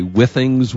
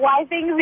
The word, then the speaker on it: Withings: And it's, yeah, apparently you can pronounce Withings in multiple different ways. Withings, Withings, Withings. – Yes. I call it Withings because it's Wi-Fi enabled products. – Right. That makes sense. – You can say Withings.